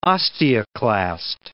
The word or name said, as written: osteoclast